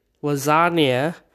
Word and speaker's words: lasagna: He is referring to the word, and specifically the one letter in the word, which is G. G, lasagna, it's silent. You cannot hear, when you are saying the word lasagna, do you hear the G? No, because the G is silent, like the real G's are.